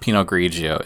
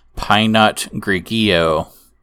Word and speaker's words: Pinot Grigio: I'm sorry, so it's not Pinot Grigio. It's Pinot Grigio.